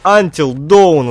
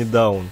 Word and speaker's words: Until Dawn: О, Until Dawn она читается. Dawn, а не Down.